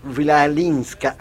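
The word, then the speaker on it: Chwalińska: Vilalinska, así. – Lo estaba practicando. – Lo estaba practicando, porque se escribe C-H-W-A-L-I-N-S-K-A.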